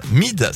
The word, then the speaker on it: Myd: Ils ont travaillé sur leur 5e album avec un DJ et producteur français qui se nomme Mid. Ça s'écrit M-Y-D.